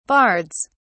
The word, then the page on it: bards: British English